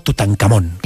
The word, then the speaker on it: Tutankamón: La Real Academia de la Lengua dice que hay que pronunciarlo y decirlo como Tutankamón.